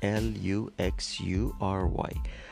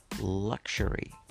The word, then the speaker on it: luxury: در بریتانیایی این ش تلفظ میشه به صورت قالب به این صورت luxury luxury luxury و در آمریکای شمالی ژ به جای ش ژ هستش luxury luxury luxury دوباره مقایسه میکنیم تلفظ قالب بریتانیایی luxury تلفظ غالب آمریکای شمالی لاکچری و تلفظ غالب در ایران لاکچری